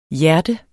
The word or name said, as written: hjerte